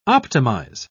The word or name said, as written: optimize